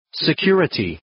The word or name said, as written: security